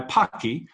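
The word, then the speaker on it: Paqui: Well, what we sent you, Jonathan, is one deadly black tortilla chip by Pocky, pronounced like hockey.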